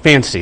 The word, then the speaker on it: FANCY: Okay, as you said, I'm with the Federal Reserve Board, my topic is on, it's called FANCY, that's how you pronounce it.